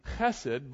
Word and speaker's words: hesed: For those of you who like to write things down, it's H-E-S-E-D. The truth is the proper pronunciation is hesed, but I want to have mercy on the people in the front rows, so we'll call it hesed.